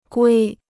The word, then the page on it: 龟: guī